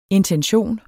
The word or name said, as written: INTENSION